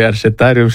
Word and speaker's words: Arșetarium: Arșe cu T, care în franceză înseamnă arcuș. – Exact. – Am spus bine. – Ați spus foarte bine, mă bucur că aici la RFI am stabilit pronunția corectă a cuvântului Arșetarium și sper de acum să nu mai fie confundată.